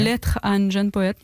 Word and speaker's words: Lettre à un jeune poète: Lettre à un jeune poète. – Okay. – How did I do with the pronunciation? Did I do okay? – Lettre à un jeune poète. Yeah.